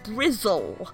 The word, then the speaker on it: Bristol: She was at the University of York for her undergrad, and then she went to the University of Bristol, which I'm told is pronounced Brizzle, for her postgrad.